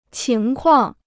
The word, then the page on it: 情况: qíng kuàng